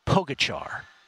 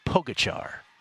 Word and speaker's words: Pogačar: His name, again, Tadej Pogačar. His name is spelled P-O-G-A-C-A-R. Pogačar, for those who missed that.